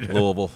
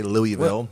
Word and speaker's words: Louisville: here, so we're actually pronouncing the name of the city a little differently than some people might be. – Well, we pronounce it 18 different ways. – Right. – Louisville. So it's named after King Louis, but we say Louisville, where I'm from, and other people around across town say Louisville.